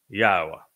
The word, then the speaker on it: Yahweh: So I have told you emphatically that God has but one name, and it is pronounced Yahweh.